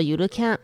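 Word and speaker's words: ゆるキャン: 摇一露营，日文又念作 Yurukan，是日本漫画家阿胡罗的漫画作品。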